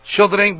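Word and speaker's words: Sjögren: ¿Es Gohren? ¿Jogren? – ¿Sjogren? – ¿Sjogren? Bueno, perdón. Sí, exactamente, sí.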